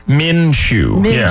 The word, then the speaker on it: Minshew: Minshew.